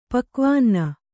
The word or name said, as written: ಪಕ್ವಾನ್ನ